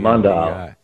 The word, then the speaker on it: Mundial: I use those Mundial, however you pronounce it, you know, M-U-N-D-I. – Mundial.